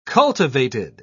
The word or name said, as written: cultivated